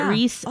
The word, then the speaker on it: Rhys: I want to say, is it Rise? R-H-Y-S? – I think that's how you pronounce it. Reese? – Is it Reese? Okay.